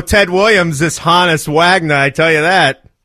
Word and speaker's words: Honus: Honass.